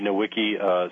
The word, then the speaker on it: Nowicki: My name is Andy Nowicki, spelled N-O-W-I-C-K-I.